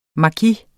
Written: maki

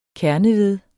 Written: kerneved

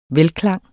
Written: velklang

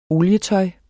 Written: olietøj

